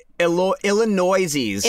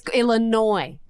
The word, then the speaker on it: Illinois: Illinoisies. – It's Illinois. – Illinoisies. I like to pronounce the S.